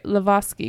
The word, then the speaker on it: Lazowski: Eugene Lazovsky. I'm going to pronounce some things wrong here, but I think that's right. Lavosky, because they're W's or V's, right? – Yes.